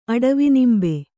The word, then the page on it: ಅಡವಿನಿಂಬೆ: Kannada